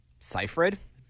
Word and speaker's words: Seyfried: Seyfried.